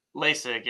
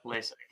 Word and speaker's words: Laessig: Is it uh, Lasig? Lasig? LASIK, yep, LASIK. Okay, LASIK.